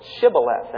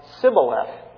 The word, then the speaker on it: Shibboleth: And they were unable to pronounce the SH sound in that word, Shibboleth. And whenever they pronounced it, it came out Sibboleth.